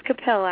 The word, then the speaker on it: Capil: I do believe I butchered your name earlier, but it is CAPIL, correct? – It's CAPIL, actually.